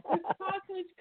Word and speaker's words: Kosich: Say it properly, Kosich or Kosich. – Kosich, Kosich.